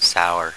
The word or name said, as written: sour